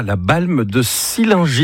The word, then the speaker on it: La Balme de Sillingy: Et bon, ce début de semaine, on accueille Laurent avec ici, Pays de Savoie, la Balme de Sillingy. Est-ce que c'est comme ça qu'on prononce l'endroit où tu vis, Laurent? – C'est parfait.